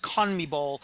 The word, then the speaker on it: CONMEBOL: He was here before when the South American Soccer Confederation, which is pronounced CONMEBOL, they held a special Copa America tournament.